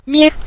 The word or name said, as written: mie5